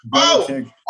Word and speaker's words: Belichick: You're going to have input, but Belichick.